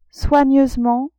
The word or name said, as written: soigneusement